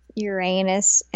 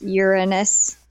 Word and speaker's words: Uranus: Yeah, um, because you guys, it's not Uranus, and it's never been Uranus. It's Uranus.